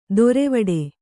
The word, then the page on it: ದೊರೆವಡೆ: Kannada